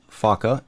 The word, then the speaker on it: Focke: On June 1st, 1939, the Focke Wulf, and that is pronounced Focke, F-O-C-K-E, the Focke Wulf FW-190 Würger was flown for the very first time.